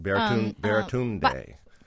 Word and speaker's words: Baratunde: I think that, is it Baratunde? I want to say his name right. – Baratunde.